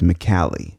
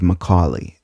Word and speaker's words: McCallie: you'll end up on a road that looks like it should be pronounced McCallie. It's just a local spelling of Macaulay, and there's actually a lot of things and people in Chattanooga that share that name, spelling and all.